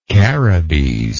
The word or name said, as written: Caribees